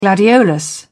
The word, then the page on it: gladiolus: British English